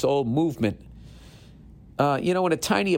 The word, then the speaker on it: MVMT: With fair prices, unexpected colors, I didn't see that coming, and clean original designs, Movement, pronounced Movement, it's MVMT, grew into one of the fastest growing watch brands, shipping to over 160 countries across the globe.